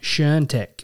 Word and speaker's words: Świątek: And then in the ladies' draw, we've got Coco Gauff, who I think's number six at the world, who's playing Iga Świątek. And I have definitely butchered that, but I went as close as I could. – Yeah. Świątek.